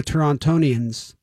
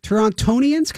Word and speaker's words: Torontonians: Torontonians? Can I say that even though we don't pronounce the T? – No.